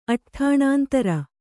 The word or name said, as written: ಅಟ್ಠಾಣಾಂತರ